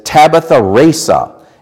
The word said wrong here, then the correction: tabula rasa: It's T-A-B-U-L-A-R-A-S-A, tabula rasa, and it means in Latin a blank slate, as in the old blackboards.